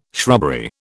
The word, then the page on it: shrubbery: British English